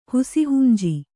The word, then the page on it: ಹುಸಿಹುಂಜಿ: kannada